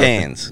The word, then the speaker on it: Cairns: Cairns. – Up in. Cairns. Yeah. But it looks like Cairns. – Yeah, it's not Cairns, it's Cairns.